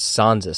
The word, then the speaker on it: Sansa: You said it like Stanza. It's Sansa Stark.